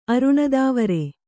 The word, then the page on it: ಅರುಣದಾವರೆ: kannada